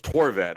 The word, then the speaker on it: Horvat: How do you pronounce his last name? Did I fuck it up totally? – It's just Horvat.